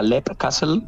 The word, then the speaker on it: Leap Castle: ¿Cómo se pronuncia? – Fíjate, yo diría Leap Castle en su día, Lip. – pero como Sean Ryan lo pronuncia Leap Castle, yo me quedé con Leap Castle.